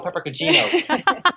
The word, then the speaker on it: peperoncino: Aglio peperoncino.